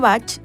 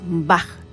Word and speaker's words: Bach: Digo Bach porque esta es su forma correcta de pronunciar. Comúnmente escuchamos Bach, pero Bach es como se pronuncia el apellido de su creador, el doctor Edward Bach, y este sistema lleva su nombre en su honor.